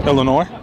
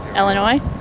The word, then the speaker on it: Illinois: Now, it sounds like it's the way you pronounce it that is spelled E-L-L, like Duke Ellington, Illinois. Where did that E sound come from?